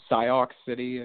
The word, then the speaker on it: Sioux City: S-I-O-U-X, Sioux City or something.